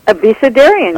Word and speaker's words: Abecedarium: I didn't pronounce that right. Abecedarium? – Abecedarium. – I did. – You got it. That's just right.